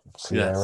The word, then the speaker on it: Ciara: yeah, I mean, good for good for I don't even know how to pronounce the name. Ciara. – Ciara. Yeah, Ciara.